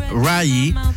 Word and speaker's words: Raye: Nos vamos a ir con esta, que es la más reciente de los lanzamientos de Mark Ronson junto a la gran Raye. Se escribe R-A-Y-E, Raye, pero se pronuncia Ray.